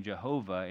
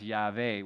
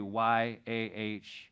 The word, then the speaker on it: Yahweh: And this is why where was confusion for many years about the exact pronunciation of the Tetragrammaton, YHWH, some mistakenly pronouncing the name Jehovah instead of Yahweh, or it's really pronounced Yahweh, which is basically YAHVEH.